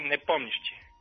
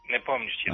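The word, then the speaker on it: Nepomnišći (Непомнящий): Jer ja znam da izgovorim Ćevapčićem ćeš me, ali Nepomnijači, kako to teže mi je. – Nepomnišći. – Nepomnišći, dobro.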